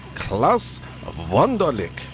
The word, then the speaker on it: Klaus Wunderlich: Yeah, but we're starting off with our favorite guy, one of our favorite guys anyway, Klaus Wunderlich.